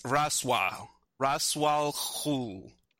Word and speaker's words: Ra's al Ghul: but it's not Ra's or Ra's. It's Ra's al Ghul.